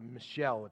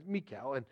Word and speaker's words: Michal: You might pronounce it Michelle if you wanted to, or come close to that. But anyway, Michal.